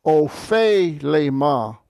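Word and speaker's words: ophelema (ὀφείλημα): Ophelema. Epsilon iota diphthong is pronounced the same as the eta, so you've got ophelema. Two eta sounds.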